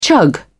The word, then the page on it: chug: British English